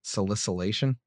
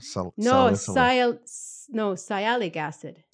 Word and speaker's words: sialic acid: And this expression of the sialic acid sialylation, how do you say it properly? – Salicylation? – Salicylation? No. – Salicylic acid or sialic acid. – Sialic acid. So that would be, yeah, you have to go to one.